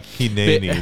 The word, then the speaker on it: Hineni: Okay, Hineni. That's how you say it in Hebrew.